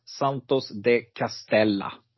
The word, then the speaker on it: Santos de Castella: Nummer nio ska uttalas Santos de Castella och ingenting annat.